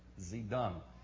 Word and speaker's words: Zedong: And that is how you say it, Zedong.